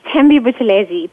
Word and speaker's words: Thembi Buthelezi: It's Thembi Buthelezi.